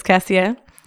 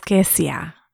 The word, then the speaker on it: Kessia: Hey, this is Kessia, a.k.a. Keisha sometimes. People call me Keish, Kess, K, but it's Kessia. And I love saying my name correctly, Kessia,